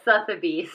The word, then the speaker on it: Sotheby's: Sotheby's.